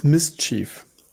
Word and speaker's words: MSCHF: Also erstmal, was ist denn dieses MSCHF und wie spricht man es eigentlich aus? – Es ist im Endeffekt das Mischief.